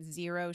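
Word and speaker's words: Xero: I'm looking at Zero Shoes. It's spelled with an X.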